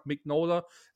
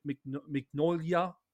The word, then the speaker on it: Mignola: Ich sage Mignola, es gibt Leute, die sagen Mignolia. Ne? Äh, wie sagst du es, AJ? – Lustigerweise sage ich Minola.